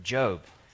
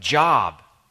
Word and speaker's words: Job: Job. It's a title that looks like job, as in teacher or doctor or barista. It's pronounced Job, though, as in Bluth.